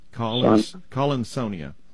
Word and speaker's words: Collinsonia: It's C-O-L-L-I-N, mm-hmm. And then S-O-N-I-A, almost like the word Sonia. Collinsonia.